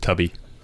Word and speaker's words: Tubi: It's pronounced Tubby. – Yes, Tubby.